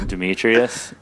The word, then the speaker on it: Demetrius: You can find me on Twitter at Now You See Me, with C spelled like my name, S-I-E. – You can find me at Kyle Demetrius. For all the people that don't know Eastern European names, it's K-Y-L-E-D-E-M-E-T-R-I-U-S.